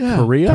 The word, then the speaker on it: Kariya: I was a big Joe Sakic fan at the time and Paul Kariya. Those were two of my favorites. – It was always an interesting last name to me. Like Kariya? – Kariya, yeah.